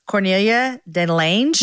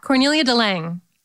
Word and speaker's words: Cornelia de Lange: Cornelia de Lange? – Yeah, Cornelia de Lange.